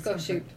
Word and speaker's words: Goshute: I mean, multiple people, like, around Skull Valley, which is also the, I might say this wrong, Goshute Reservation? – No, it is Goshute.